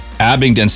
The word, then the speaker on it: Abingdon: I hope I said that right. Abingdon, Maryland. – Abingdon sounds Abingdon. Much more professional.